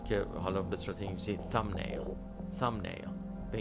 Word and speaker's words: thumbnail: تام نیل